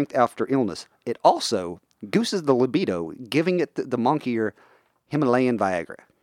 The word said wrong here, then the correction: moniker: Okay. Monkier? – The monkier. Monkier? – Moniker? – Moniker? Is that it? – Spell it for me. – Monkier. – Moniker. – Okay. It looks like monkier.